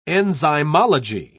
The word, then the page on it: enzymology: British English